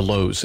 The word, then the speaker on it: Louhs: We are on Facebook, Twitter, Instagram, and on YouTube, all under the headings of The Lows, and that last name is spelled L-O-U-H-S.